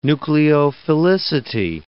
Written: nucleophilicity

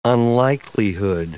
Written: unlikelihood

